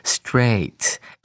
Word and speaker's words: straight: 我们看到第一个单词 straight。注意它的发音跟 spelling 之间的关系，你是否有注意到这个 g h 是不发声的呢？而 a i 呢，念成长母音。